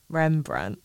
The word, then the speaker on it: Rembrandt: And there was a lot of, I always say his name is Remembrandt, but I know that it's Rembrandt.